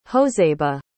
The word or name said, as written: joseba